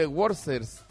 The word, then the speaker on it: Worcester: A, ¿cómo se cerca el de Boston hay un pueblo que se llama, se escribe Worcester, fíjate vos. – Como que y si fuera alemán suena sí, eso. – Y suena Worcester.